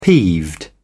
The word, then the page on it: peeved: British English